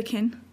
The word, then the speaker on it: Qin: The Qin. – Qin dynasty.